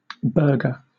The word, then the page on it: burger: British English